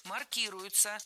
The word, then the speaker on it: маркируют: Было сказано «маркируют», «маркируются», но это неверно. Правильно, «маркируют», «маркируются». Дело в том, что перед нами омограф, то есть слово, которое имеет несколько значений, и ударение зависит от значения. «Маркировать», «маркироваться» – этот глагол используется в лингвистике. «Маркировать», «маркироваться» – это слово общего употребления, означает «ставить клеймо», «марку», как в нашем примере.